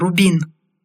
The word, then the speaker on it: Рубин: «Что оно умеет?» Многое, Рубин. Ударение на «у» — это моя фамилия.